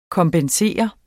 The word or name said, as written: kompensere